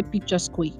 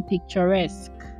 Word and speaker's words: picturesque: Oh, it is so, and I will not lie, I used to say picture square. Oh my god. Oh, what? I used to say picture square. When the pronunciation is actually picturesque.